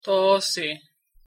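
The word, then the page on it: toci: Nahuatl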